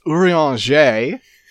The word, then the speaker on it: Urianger: Oh, so first off, it's Urianger.